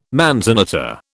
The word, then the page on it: manzanita: British English